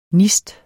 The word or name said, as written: nist